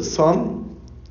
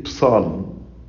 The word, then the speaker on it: psalm: The word psalm is pronounced with silent P. We don't say psalm, but we say psalm.